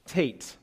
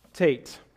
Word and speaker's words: teth: So this, every single eight verses in this section begins with the Hebrew word tate. Tate. Your translation may look like teth, T-E-T-H, but it is pronounced more closely to tate.